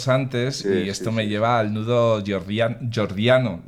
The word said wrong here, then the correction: gordiano: ¿Lo he dicho bien? Jordiano. – Gordiano. – Gordiano, sí, eso.